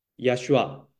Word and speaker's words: Yeshua: 耶稣是希腊文的发音，他希伯来的发音呢，就是 Yeshua，就是约书亚。